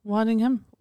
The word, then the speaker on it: Waddingham: How would you say her name? – Hannah Waddington. – Waddingham? Waddingham. Waddingham. Yeah.